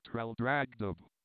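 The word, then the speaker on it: Traldragdubh: It contained a warrant for conducting me and my retinue to Trildragdub, or Trildragdrib, for it is pronounced both ways, as near as I can remember, by a party of 10 horse.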